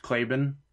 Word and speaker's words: Claiborne: But, John, you pronounced it wrong. It's pronounced Claibin. – Give me a... – Is it? – It's 100% Claibin.